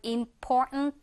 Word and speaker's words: important: 근데 이 경우 여러분들 발음이 important 이런 식으로 이 트 발음이 약간 먹힌다고 해야 될까요? 이렇게 들리거든요. 한번 들어보면서 따라해 보세요, 발음.